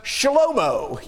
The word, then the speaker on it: Shlomo: Now, Solomon in Hebrew text, his name is pronounced Shlomo, you know, Shlomo, Shalom-o.